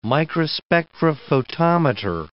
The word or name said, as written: microspectrophotometer